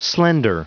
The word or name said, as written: slender